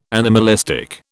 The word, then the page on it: anomalistic: British English